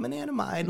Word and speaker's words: anandamide: I'm anandamide. Let me get in there." – I still think he's pronouncing it wrong, but okay. – Anandamide. Anandamide. Sorry. Correct me, please.